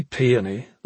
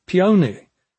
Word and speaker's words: peony: "He doesn't say peony, like you or me," Pitten said. "He says peony." The word in this pronunciation rhymed with pony.